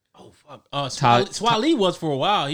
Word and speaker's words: Swae Lee: I never knew how to pronounce that shit. – Like, it's Swae Lee.